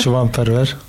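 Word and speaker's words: Şivan Perwer: Et du coup, c'est un chant composé par le poète kurde Shivan Perver. Je ne prononce pas très bien, me corrigeras. – Shivan Perver.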